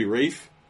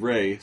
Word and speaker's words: Ralph: It's actually Rafe. – Is it Rafe? – It's Rafe. – That's a cool name. – When I looked it up, I had heard Ray, so I think I was pronouncing it Ray.